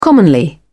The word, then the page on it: commonly: British English